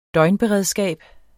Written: døgnberedskab

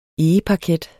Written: egeparket

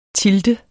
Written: tilte

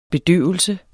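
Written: BEDØVELSEN

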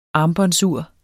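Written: armbåndsur

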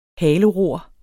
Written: haleror

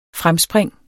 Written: fremspring

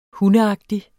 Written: hundeagtig

